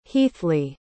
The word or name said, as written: heathley